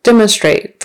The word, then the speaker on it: demonstrate: Demonstrate. First syllable stress.